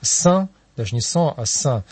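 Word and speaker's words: sang: По-французски кровь будет «сан», но это я прочитал, а пишется «санг» на конце буквы «г», и так.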